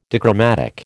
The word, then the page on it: dichromatic: American English